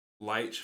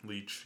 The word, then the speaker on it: Leitch: My theory on why this movie got made is Leitch fell in love. That's how you say his name. David Leitch fell in love with... – Leitch, yeah.